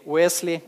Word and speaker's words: Уэсли: Весли или Уэсли, как его по-разному по-русски произносят.